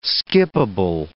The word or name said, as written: skippable